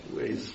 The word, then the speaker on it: Waze: Waves. – Waves, W-A-Z-E.